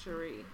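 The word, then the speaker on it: Sheree: The next thing that I wanted to talk about was the photo of Will Smith and the mother of his child, Cherise. I think that's how you say her name. – I think it's just Cherie.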